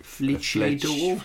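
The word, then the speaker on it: flèche d'or: It said flesh door, F-L-E-C-E with a French accent on the E. – Was there an H in there? – Fleche door.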